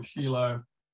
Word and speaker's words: Shiloh: I'm not sure of the story of how he came to know the Lord, but he met up with someone in Shiloh or Shiloh, as it's actually correctly pronounced if you're not Australian, and she was already doing some sort of therapy.